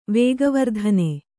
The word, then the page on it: ವೇಗವರ್ಧನೆ: vēga vardhane